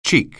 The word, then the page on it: cheek: English